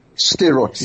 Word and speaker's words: Sderot: Sderot, yes.